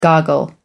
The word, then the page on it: goggle: American English